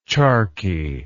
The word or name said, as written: charquid